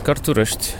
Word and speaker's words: Cărturești: So this is Cărturești. Is that how you pronounce it? – Yes, Cărturești.